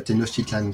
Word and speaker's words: Tenochtitlan: alors en Awa, c'est Tenochtitlan, c'est un, un CH. – D'accord. Tu dire euh, Ténoc, comme ça, au moins, hein, c'est bon. – Voilà, Tenochtitlan, de conçu pour offrir de nombreuses possibilités pour des groupes mixtes et de changer le devenir de cette région.